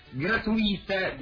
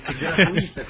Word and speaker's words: gratuita: il servizio di distribuzione gratuita dei podcast gratuita con l'accento sulla I.